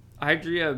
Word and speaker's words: hydrangea: Panting, Harry fell forward over on the hydrangea bush. How do you say it? Hydrangea bush? – Hydrangea. – Hydrangea.